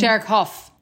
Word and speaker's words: Derek Hough: No, she wanted Derek Ho, the guy from... – Derek Hough.